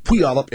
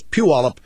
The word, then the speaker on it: Puyallup: I mispronounced Puyallup, Washington. I call it Puyallup. It's Puyallup.